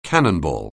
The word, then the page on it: cannonball: anglais